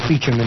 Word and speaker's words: Beauchamp: Beauchamp, pronounced Beecham in England.